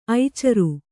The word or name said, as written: ಐಚರು